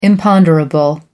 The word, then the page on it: imponderable: American English